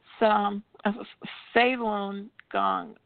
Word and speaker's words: Falun Gong: It's Falun Gong. It's F-A-L-U-N-G-O-N-G. It's two words. Persecution of Falun Gong. That's how you say it.